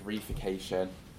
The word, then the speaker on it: reification: And then from that, looking at feminist historiography and doing what I've talked about, the rification, I apologise for the pronunciation, I've only read it. I said reification.